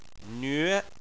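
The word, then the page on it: nve: tone 2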